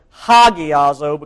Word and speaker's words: hagiazo: I should pronounce this one hagiazo because of the little strong breathing mark on top of the first letter. Hagiazo. Hagios, to be made holy.